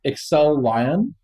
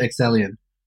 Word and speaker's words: Excellion: I believe the correct pronunciation is Excel Lion. It's like the Microsoft Excel produced a lion. – You got it. – Is that right, Samson, or is it Excellion? – It's both.